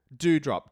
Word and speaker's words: Doudrop: Doudrop. D-O-U-D-R-O-P, but pronounced do like mountain dew.